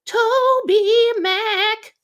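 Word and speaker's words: TobyMac: I think Abandoned Kansas is on Goatee Records, which is Toby Mac. Toby Mac. That's the correct pronunciation.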